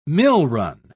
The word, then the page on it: millrun: British English